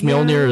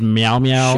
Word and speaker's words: Mjolnir: She pronounced Mjolnir as Meow Meow.